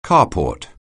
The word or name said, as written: carport